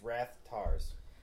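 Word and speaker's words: Rathtar: I keep calling it Wrath-gars. – No, I thought it was Wrath-nars. – I just remembered it. It's Wrath-tars. – I think Finn and Han were pronouncing them differently. – No, they both said Wrath-tar.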